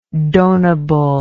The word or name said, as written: donable